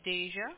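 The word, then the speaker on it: Daesia: My name is Deja.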